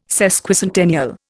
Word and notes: Sesquicentennial: Indian

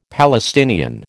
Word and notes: Palestinian: American English

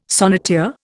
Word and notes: sonneteer: Indian

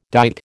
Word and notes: dight: American English

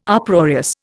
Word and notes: Uproarious: Indian

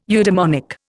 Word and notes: eudemonic: Indian

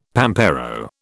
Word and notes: pampero: British English

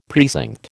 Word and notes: precinct: American English

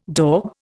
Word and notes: daube: Indian

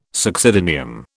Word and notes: succedaneum: British English

